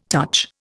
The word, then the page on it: touch: Indian